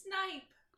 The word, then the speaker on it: snipe: Snipe. – What did I say? – A Snape hunt. – You put it... Oh, fuck, I forgot you were trolling me on that. She trolled me on this like 3 weeks ago and was like, nah, you'll say it wrong and you'll forget.